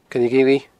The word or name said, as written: coilleghillidh